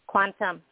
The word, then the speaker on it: QuanTâm: Uh, help me if I'm pronouncing it incorrectly, QuanTâm. – QuanTâm, yes. So mm-hmm.